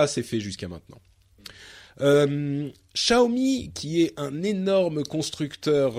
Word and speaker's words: Xiaomi: C'est Xiaomi. – Xiaomi.